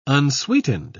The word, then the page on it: unsweetened: British English